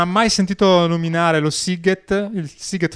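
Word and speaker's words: Sziget: Eh sì, SZ si pronuncia come la nostra S dolce sostanzialmente, quindi... Sighet, come sigaro appunto ti dicevo, quindi è la stessa cosa.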